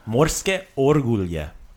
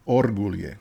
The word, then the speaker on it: Orgulje: So in this episode of Super Slotko, I'm talking about the Morske Orulje. Did I get O-O-O-O-O-O? – Nope. Morske Orgulje. – Orgulje. – Orgulje. – Orgulje. – So give me that accent on the first syllable. Orgulje. – Orgulje.